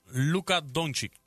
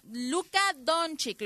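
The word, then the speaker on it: Luka Dončić: Luka Doncic. – Don, Luka Doncic, ¿lo dije bien? – Sí.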